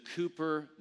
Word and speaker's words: Cowper: And he said, there in that little town is the Cooper Newton Museum. Now, it looks to us Americans like Cowper, C-O-W-P-E-R, but we have to pronounce it like a good Brit, so Cooper, the W is silent.